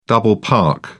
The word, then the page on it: double park: British English